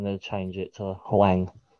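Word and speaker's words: Hwang: I've just gone through the transfers and I've just noticed that I don't know if I'm going to say his name right, but is it Huang from Wolves? – Yep.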